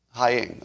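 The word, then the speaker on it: Heying: Give me the proper pronunciation, because I know I'm getting both of their names wrong. Haying, okay.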